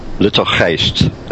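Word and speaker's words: Luttelgeest: And I think this is pronounced Lutelgeest. – Lutelgeest is how it's pronounced, actually, okay, in Dutch.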